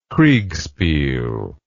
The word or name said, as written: kriegspiel